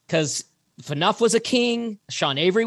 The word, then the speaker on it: Phaneuf: And Dion Phaneuf. I'm going to look to you. Am I pronouncing that right? – Phaneuf.